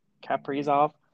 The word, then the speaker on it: Kaprizov: Yeah, Kaprizov.